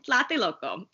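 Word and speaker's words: Tlatelolco: Loco. Plate Loco. Okay. – That sounds good. Yeah, that sounds spot on.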